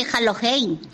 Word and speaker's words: Halloween: No. Se dice Halloween.